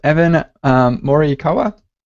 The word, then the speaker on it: Morikawa: And for our esteemed guests, we have Evan Morikawa. – Yep. Good morning. – I didn't ask you if I pronounced your name right. So was that? – Yeah, that's right. Evan Morikawa. That's pretty good.